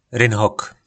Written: rynhok

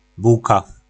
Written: bulka

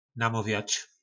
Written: namovjac

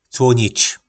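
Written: Cłónič

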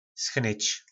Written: schnyc

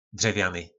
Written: drevjany

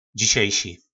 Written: dzisejsi

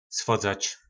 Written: svodzac

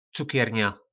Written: cukerna

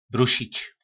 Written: brusic

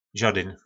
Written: zodyn

